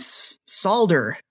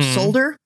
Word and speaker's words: solder: He said solder. Mm-hmm. Or solder. – Mm-hmm. – And I believe the American pronunciation is we do not pronounce the L.